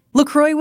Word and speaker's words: LaCroix: It's LaCroix. It's pronounced LaCroix.